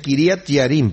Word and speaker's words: Kiriat Jearim: Y desde ese día en que el arca quedó en Kiriat Jearim, se pronuncia Kiriat Jearim, porque la J en hebreo no se pronuncia como J, sino como Y.